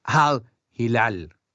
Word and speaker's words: Al Hilal: Bueno, no se preocupen que al ratito hablamos de lo que hizo el Al Hilal. ¿Así, así se pronuncia, Leo? ¿Tú que has estado en, en Medio Oriente? – Por supuesto, así mismo, sí, sí, sí, sí, Muy bien, muy correcto.